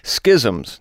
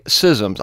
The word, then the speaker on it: schisms: Now, some might say schisms. I looked this up. That's a dual pronunciation. You can either say schisms or schisms. I used to like to say schisms, but I'm now thinking schisms might be better.